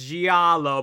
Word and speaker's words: giallo: Giallo. – I took Italian diction. You have to pronounce each vowel, so it's giallo, but when you say it fast enough, it's just giallo.